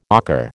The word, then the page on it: ocker: American English